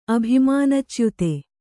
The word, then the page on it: ಅಭಿಮಾನಚ್ಯುತ: Kannada; abhimānacyuta